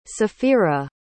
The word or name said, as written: safira